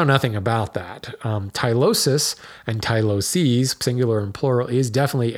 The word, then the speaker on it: tyloses: And probably I should be referring to it as Tyloses, singular being Tylosis, plural being Tyloses.